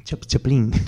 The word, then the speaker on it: Chaplin: Chaplin. – Claro, Carlitos, sí. Chaplin le decimos. – Claro, porque lo acentuáis siempre en la última sílaba.